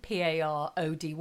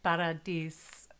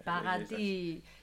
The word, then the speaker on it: Paradis: I'm saying it like Parody, as opposed to Paradis. – Well, I mean, in French, you say Paradis, don't you? – Paradis. Yes, yeah.